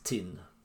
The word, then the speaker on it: Thin: Med Thin, som alltså oftast uttalas tin på dialekt då.